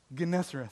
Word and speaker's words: Gennesareth: How do you say that word? Yeah, you always know. You always know. It's like, did you say it? The big word. The what? Gennesareth.